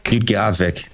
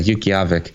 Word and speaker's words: Utqiagvik: It says, the borders of the Alaska Triangle connect Anchorage and Juneau in the south to Utqiagvik. Is that how you say that? Utqiagvik? – Utqiagvik. Yeah, Utqiagvik.